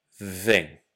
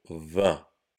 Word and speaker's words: vin: Je vais d'abord dire le mot vin comme moi je le prononce et ensuite comment vous pourrez l'entendre dans d'autres régions. 20. Maintenant, comme à Paris par exemple, 20. Écoutez bien encore une fois. 20. 20. C'est le même mot, mais l'accent fait que on ne le prononce pas pareil.